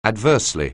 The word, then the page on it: adversely: anglais